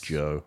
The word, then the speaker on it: Djo: He's currently releasing music under the name Joe. I think that's how you pronounce it. D-J-O. – Nice. – Joe. – Got that D in there.